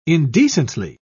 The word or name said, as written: indecently